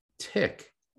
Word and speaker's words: Thich: And thick, T-H-I-C-H, pronounced tick, is, and that translates.